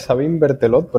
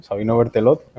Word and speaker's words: Sabino Berthelot: ¿Cómo se pronuncia correctamente? – Bueno, el nombre real es Sabine Bertelot, pero aquí en Canarias pues, se le conoce como Sabino, ¿no? Sabino Bertelot, hemos, digamos, convertido el nombre al canario.